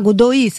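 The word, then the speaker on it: Godói: Alors peut-être que je prononce mal Godoy, on doit dire Candido Godoy. – Godoy. – Ah, vous connaissez? – Non, mais c'est pas Godoy, c'est Godoy. Déjà, on lit, il faut savoir lire. – Ça s'écrit G-O-D-O-I avec un petit accent sur le O.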